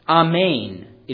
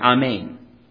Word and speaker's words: Amen: It is pronounced Amen in Greek, and in Hebrew it's about the same thing, Amen.